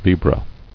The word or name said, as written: Libra